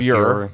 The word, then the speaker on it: Bure: I called him Pavel Bure because it looks like pure, but pure, it's Bure, B-U-R-E.